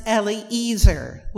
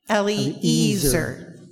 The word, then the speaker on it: Eliezer: It's pronounced Eliezer. Will you say that after me? Eliezer.